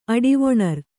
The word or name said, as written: ಅಡಿವೊಣರ್